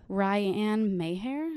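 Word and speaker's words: Rianne Meijer: Oh, she's Ryan Mayhair.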